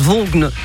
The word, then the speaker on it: Vaughan: Alors, il paraît que ça se prononce Vaughan. J'ai jamais pu le dire.